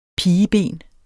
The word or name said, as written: pigeben